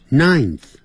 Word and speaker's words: ninth: 第九不是 N-I-N-E 了，它是 N-I-N 再加个 TH ninth。